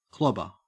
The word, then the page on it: clobber: Australian English